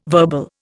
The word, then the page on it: verbal: Indian